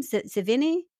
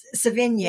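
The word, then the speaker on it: Sevigny: So, and I just think it's worth pointing out that the female leads in this, Calista Flockhart, Diane Lane, Naomi Watts, Chloe, how do you say her last name? Savini? – Savigny.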